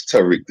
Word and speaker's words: Tariq: How do those things come together to make what, and is it Tajriq? Am I pronouncing it correctly? – No, it's Tariq, the JSI.